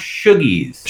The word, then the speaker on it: Shoogies: It's actually pronounced Shogies. – Shogies. – S-H-O-O-G-I-E-S.